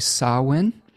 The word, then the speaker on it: Samhain: It is also associated with a cross holiday, which I believe is pronounced correctly, Samhain, spelled Samhain.